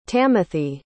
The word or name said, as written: tamithy